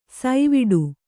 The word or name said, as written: ಸೈವಿಡು